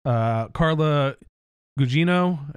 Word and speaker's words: Carla Gugino: Zack Snyder had talked about wanting to cast Catwoman in this, and he wanted somebody who he's worked with in the past, Carla Gugino.